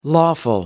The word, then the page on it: lawful: American English